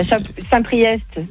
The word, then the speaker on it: Saint-Priest: T'as Saint-Priest, c'est EST, tu le prononces. Chaponneau, c'est ST, tu le prononces pas.